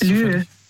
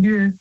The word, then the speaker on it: lieu: Lieu. – So Lü. – Lü? – Also Lü, das schreibt man also L-I-E und dann U.